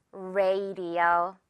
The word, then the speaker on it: radio: 하지만 이 A가 바로 A 발음 나는 A라서요. Ray가 발음이 됩니다. Radio. 영어로는 라디오 아니고요. Ray. 마치 비 올 때 그 rain처럼 그 발음 그대로 가지고 와서 radio 해주면 돼요.